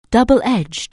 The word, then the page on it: double-edged: anglais